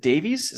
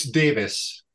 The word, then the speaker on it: Davies: So it's funny you ask that, because I saw an interview where the singer of the Kinks, Ray, is it Davies? Is that how you say his name? Ray Davies? – It's Davis. – Davis. – Davis. Yeah. – It's spelled Davies, but it's pronounced Davis. – It is. Okay. It looks like it says Davies, but it's a British spelling.